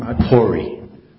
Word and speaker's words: Pori: Pori. – P-O-R-I.